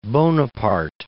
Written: Bonaparte

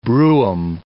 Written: brougham

